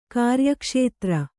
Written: ಕಾರ್ಯಕ್ಷೇತ್ರ

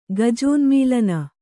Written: ಗಜೋನ್ಮೀಲನ

